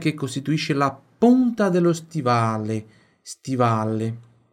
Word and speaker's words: costituisce: La Calabria è la regione che costituisce la punta dello stivale.